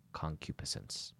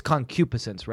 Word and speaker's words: concupiscence: A good segue, and I'll allow it, because we're talking about lust and concupiscence. – Yeah. – It's difficult to pronounce. I'm pretty sure that the accent is on the antepenult of that word, but I'm sorry if I'm wrong.